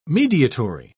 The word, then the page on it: mediatory: British English